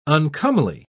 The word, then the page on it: uncomely: British English